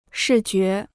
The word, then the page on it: 视觉: shì jué